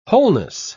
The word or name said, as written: wholeness